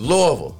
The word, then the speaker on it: Louisville: It's Louisville.